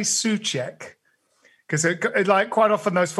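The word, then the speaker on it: Souček: Well, just to throw something further in there, my understanding is that it's pronounced tss. Soucek. So it's like a T at the beginning. Soucek.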